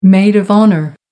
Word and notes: maid of honour: American English

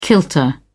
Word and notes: kilter: British English

